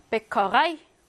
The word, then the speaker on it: Bäckerei: Bäckerei. 이거는 아이로 발음하죠? 아이.